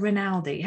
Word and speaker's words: Rinaldi: This week I have the wonderful Kendra Rinaldi.